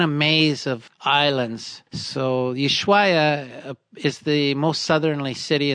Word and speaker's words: Ushuaia: Yeah, Ushuaia.